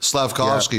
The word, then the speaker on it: Slavkovsky: Slavkovsky, yeah.